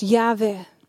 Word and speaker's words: Yahweh: J-H-W-H, volgens sommigen uitgesproken als Yahweh.